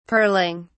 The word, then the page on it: pearling: British English